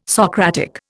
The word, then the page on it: Socratic: Indian